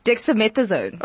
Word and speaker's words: Dexamethasone: Dexamethasone.